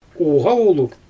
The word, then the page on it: uɣawuluɣ: Xyzyl